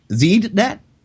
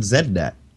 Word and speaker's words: ZDNet: So I'm just throwing the link to the to the uh, hey it's it's spelled Z D N E T. How would the Americans pronounce that? Zednet? Yeah, I guess so. I've never, I never, I, I never thought about that before. I've always called it ZNet because it just looks right.